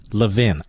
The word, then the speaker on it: Levin: Will somebody get a hold of Gutfeld and tell him it's pronounced Levin? I happen to think he knows this. It's not Levin, not Levine, Levin. Lovin' Levin. That's it.